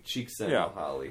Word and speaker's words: Csikszentmihalyi: So anyway, Mahali Csikszentmihalyi.